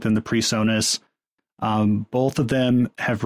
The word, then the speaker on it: PreSonus: Oh, Presonus.